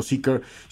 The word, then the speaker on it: Zeekr: Seeker C01 mire, si usted entra a internet y busca Seeker, por cierto, Seeker, si usted lo ve en algún vehículo, lo pronunciamos así, me parece que en la conferencia también se pronunció como Seeker, empieza con Z, lleva doble E, lleva una K y una R, Seeker, el, el 001, eh, pues un vehículo plenamente, plenamente deportivo.